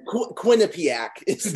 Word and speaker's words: Quinnipiac: So you graduated to, you were, what's the Quinnipiac? Am I saying it right? – Quinnipiac.